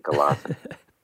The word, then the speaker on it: Gelassenheit: Anyway, that's the anglicized version, Gelassenheit.